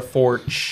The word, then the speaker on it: Forcht: Today we have myself, Joey, Kevin, and special guest, Sarah Forked. Did I say that right? Forged? – We were just talking about that. Apparently it's Forch. Yes. – Forch? – Yeah. – Silent T? – Silent yes. T.